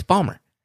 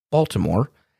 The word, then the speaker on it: Baltimore: What do you mean by, as I would say, It's how you say it. It's Bawlmer. Okay, well, I mean, you know, I think some people might say that you pronounce it Baltimore. Yeah, you know who says that? People that ain't from Bawlmer.